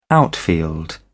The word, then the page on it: outfield: British English